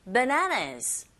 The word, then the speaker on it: bananas: 그리고 바나나가 아니라 bananas. bananas 강세가 뒷부분에 있고요. that we harvested.